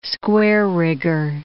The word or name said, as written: square-rigger